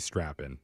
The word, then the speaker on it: Strappon: S-T-R-A-P-P-O-N. Some people pronounce it Strap-On, but it's actually Strappon.